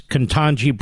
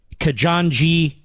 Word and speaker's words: Ketanji: Biden also managed to make a rather severe gaffe during his remarks at the church on the weekend by butchering the name of Supreme Court Justice Ketanji Brown Jackson, calling her Kajanji Ketanji Drown Jackson.